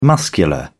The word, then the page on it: muscular: British English